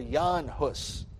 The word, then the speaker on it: Jan Hus: But before that, you had John Hus, or Jan Hus, as it's sometimes pronounced.